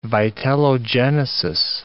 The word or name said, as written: vitellogenesis